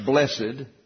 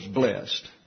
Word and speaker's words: blessed: English versions translate this adjective, Macarius, blessed, pronounced blessed. I always thought it was blessed, okay? And I didn't get the second syllable there. But people want to have the word blessed, which is okay. I looked it up in the dictionary to see if there was such a word as blessed, like two syllables.